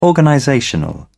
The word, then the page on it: organizational: American English